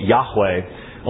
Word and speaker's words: Yahweh: In fact, the divine name in the Old Testament, Yod-Heh-Wal-Heh, Yahweh, only has two syllables. But Jehovah has three.